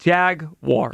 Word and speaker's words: Jaguars: There's a lot of people that do the wires thing. It's wars. – It's got to stop. – Jaguars. – Yeah. It's not that difficult. It's U-A-R.